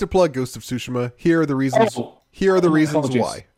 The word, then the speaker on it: Tsushima: Ghost of, how do you say it? – Tsushima. – Tsushima? – Yeah. – Tsushima. It says, Ghost of Tsushima.